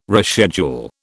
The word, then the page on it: reschedule: British English